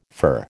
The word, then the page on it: fir: American English